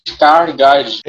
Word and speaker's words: Skarsgård: E o Gustav, eu não sei como é que se pronuncia, Skarsgård faz o Flock também. – Skarsgård, sim. – O Flock também é um puta personagem. – Gustav Skarsgård.